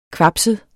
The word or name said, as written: kvabset